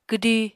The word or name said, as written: kwdi